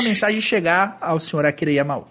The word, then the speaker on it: Yamaoka: Ah, é Yamaoka que fala? – Yamaoka. Falei errado.